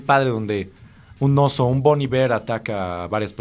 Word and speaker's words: Bon Iver: También está Bon Iver, que se pronuncia totalmente como se escucha, Bon Iver.